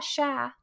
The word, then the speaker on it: cher: I do wish in this game, whenever you see something very cute, it's very common to say, oh, sha, which is C-H-E-R, like share, which means deer, but it's pronounced sha.